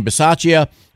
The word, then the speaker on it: Bisaccia: The players wanted Rick, and I always mess up his name, Bisaccia. Did I get it? Did I say his name right? – Oh, yeah, you said it. Yeah, Rick, okay. – Bisaccia.